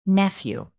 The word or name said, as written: nephew